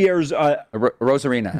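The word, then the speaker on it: Arozarena: This team, you know, when you look at Randy Rosarena.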